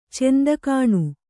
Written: ಚಂದಕಾಣು

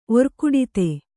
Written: ಒರ್ಕುಡಿತೆ

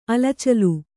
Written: ಅಲಚಲು

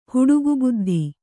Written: ಹುಡುಗುಬುದ್ಧಿ

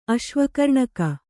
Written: ಅಶ್ವಕರ್ಣಕ

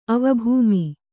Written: ಅವಭೂಮಿ

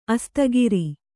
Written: ಆಸ್ತಿಗಾರ

